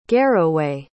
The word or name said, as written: garroway